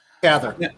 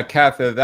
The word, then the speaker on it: Cather: Uh, in our last week's conversation about uh, Willa Ka- Cather, Cather, I'm never quite sure how to pronounce her last name. – Cather. Uh, Cather